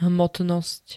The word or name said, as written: hmotnost